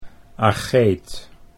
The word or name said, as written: Ageeth